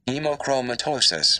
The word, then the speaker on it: hemochromatosis: So this disease, hemochromatose. Yeah, hemochromatose causes patients to... I know we have to say it right. All right, so this particular disease, this... – Hemochromatosis. – That's pretty close.